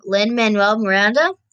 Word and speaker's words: Lin-Manuel Miranda: And the creator of Hamilton, sorry if I say this wrong, Lin-Manuel Miranda, I said it right, actually wrote this one, and it's really cool.